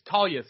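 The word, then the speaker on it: Taila: The I should be after the L, correct? – If it was Talia. It's Talia Santos. – It should be Tail-A Santos. That's how it's spelled.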